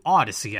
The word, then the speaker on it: Audacy: A reminder, you could hear this podcast on Apple Podcasts, Google Podcast, Spotify, Stitcher, or the new Odyssey app, which is spelled A-U-D-A-C-Y, not Homer's Odyssey, but the Odyssey app.